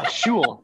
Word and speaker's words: Shewell: Hey, Shewell? How's it going? Yeah, Shewell. Yep, you got it.